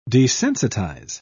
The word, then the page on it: desensitise: British English